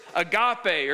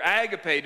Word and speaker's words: agape: This word is agape or agape, depending on how you say it.